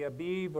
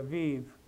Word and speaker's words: Abib: Depending on your translation, it could be Abib or Aviv, depending on how you pronounce the bet in the Hebrew language.